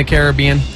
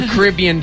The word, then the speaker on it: Caribbean: That's a two-island country in the Caribbean. – Okay, good. – Or Caribbean, depending on how you say it.